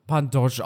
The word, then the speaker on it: Pantoja: Wenn es nach Pantoja geht, spricht man ihn, ich glaube, Alejan Pantosch aus.